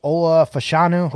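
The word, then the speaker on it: Olu Fashanu: Next up, 4, Washington Commanders, Ola Fashanu.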